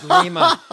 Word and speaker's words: Lima: So, you know, he said, okay, you want me to save some Lima plant? Okay, like Lima beans, we'll do that. And they said, no, Mr. President, it's pronounced Lima.